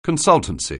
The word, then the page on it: consultancy: anglais